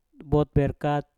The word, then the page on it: bot: mpur